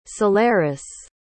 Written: solaris

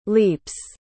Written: leaps

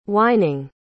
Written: whining

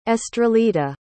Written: estrellita

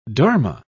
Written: dharma

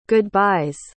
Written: goodbye's

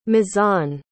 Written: mizan

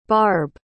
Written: barbe

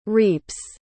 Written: reaps

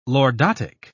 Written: lordotic

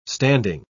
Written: standing

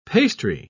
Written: pastry

